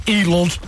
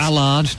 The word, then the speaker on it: Aled: Allard is spelled A-L-E-D. Not Allard. Not Allard. Not Elod. – It's Allard. – Allard. – No. – A-L-E-D. Allard. Allard. A-L-E-D. End of.